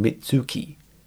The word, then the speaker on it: Mitsuki: Frank declares the booze is flowing like mud, then notices Gail's wearing Mitsuki, rhymes with nookie.